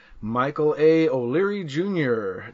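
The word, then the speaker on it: Michael A. O'Leary Jr.: With this week's guest, Michael A. O'Leary, Jr. Did I say your name right? – Yes, you did.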